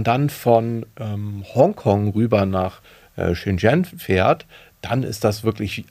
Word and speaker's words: Shenzhen: Also Shenzhen, das habe ich da gelernt. So ähnlich wahrscheinlich wird es ausgesprochen. Shenzhen sagen hier natürlich die meisten.